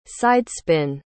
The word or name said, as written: sidespin